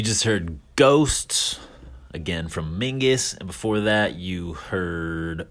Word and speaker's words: MYNGVS: You pronounce it Mingus, but it's spelled with the V instead of the U.